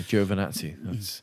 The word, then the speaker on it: Giovinazzi: Yeah, Giovinazzi. That's how, after the debate last year, that's how Antonio Giovinazzi said that's how you pronounce my name.